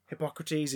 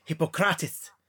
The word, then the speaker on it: Hippocrates: Hippocrates is Hippocrates.